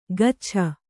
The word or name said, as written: ಗಚ್ಛ